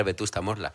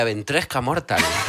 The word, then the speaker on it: Vetusta Morla: que una vez después de un concierto no podía pronunciar Vetusta Morla. Y decía, Ventresca Mortal.